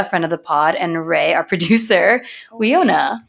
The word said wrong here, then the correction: Winona: So I'm sure most of you must be thinking, wow, they have no idea how to pronounce Winona. And you're right, one of the 4 of us had no idea how to pronounce Winona Ryder, famous movie star and Joyce Byers on Stranger Things.